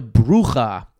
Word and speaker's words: bruja: And they have to keep a watchful eye to make sure that the bruja, I think it is pronounced, B-R-U-J-A, you've heard of brujas.